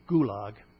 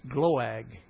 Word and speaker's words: Gloag: I say Gulag. It's Gloag. I never can say it right even to myself. G-L-O-A-G.